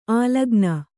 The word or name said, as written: ಅಲಕ್ಕನೆ